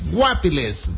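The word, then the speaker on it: Guápiles: Guapiles.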